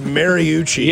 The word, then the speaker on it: Mariucci: It's pronounced Mariucci.